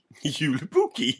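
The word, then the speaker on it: Joulupukki: Yulupukki? – Yulupukki.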